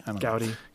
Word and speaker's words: Gaudí: Gaudi.